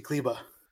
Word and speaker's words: Kleber: Next question comes from Stokely, who asks, why is Maxi Kleber so handsome? – Oh, my God. Why did you say it like that? – Sorry. Maxi Kleber. – Thank you.